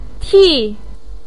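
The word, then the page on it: ti: tone 4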